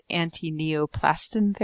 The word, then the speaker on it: antineoplastin: The $30,000 mark was chosen because that's about the cost of what Brzezinski charges for his antineoplastin therapy.